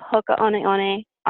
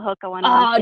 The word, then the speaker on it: Hoka One One: Obviously I love Hoka One One. A lot of people call it Hoka One One.